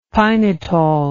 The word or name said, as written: pinitol